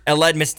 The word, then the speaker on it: Aledmys: Aledmys Diaz.